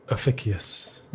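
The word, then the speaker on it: Ophiuchus: This was in the constellation of, I can't pronounce this. What's that there? – Ophicius. – Is it? I'm glad you pronounced that.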